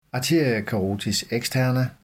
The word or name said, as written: a. carotis externa